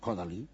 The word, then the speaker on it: Kodály: Yo había estado en Hungría, en Budapest, había ido al instituto de kodály Soltán Kodal y como dicen los húngaros que pronuncian primero el apellido, Soltan Kodali, era el maestro de Bela Bartok.